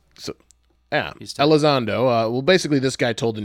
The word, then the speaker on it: Elizondo: Luis Elizondo, I think that's how you say it. – Elizondo. – Elizondo, okay.